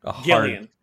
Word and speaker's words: Gillian: Gillian with a hard G. – Gillian.